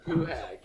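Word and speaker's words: Pohtack: Poohak.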